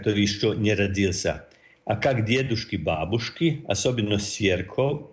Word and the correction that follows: свекровь: или правильно сказал, мама-супруга. – Свекровь, так.